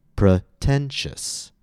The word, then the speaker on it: pretentious: It's not going to be a full open E sound. It's going to be something more like pr or pri. The end result is that the word is pronounced pretentious.